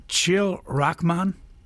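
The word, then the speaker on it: Chil Rajchman: Correct my pronunciation at any time. Chil Rahman? – Ah, yes, yes.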